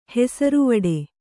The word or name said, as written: ಹೆಸರುವಡೆ